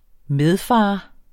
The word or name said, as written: medfar